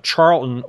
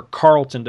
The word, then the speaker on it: Charlton: It was, I think Gold Key may have done some of the reprints, but it was actually Charlton or Carlton, depending on your pronunciation.